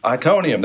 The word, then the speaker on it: Iconium: How do you say that? Iconium? – Iconium, thank you.